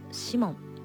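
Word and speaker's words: 鰤門: 東京銀座と日本橋にある江戸前寿司のシモン、漢字でお魚のブリという字に建物の門と書いてシモンと読みます。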